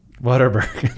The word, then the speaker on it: Whataburger: Whataburger. There's no R in there.